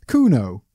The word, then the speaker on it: Kuno: Kuno.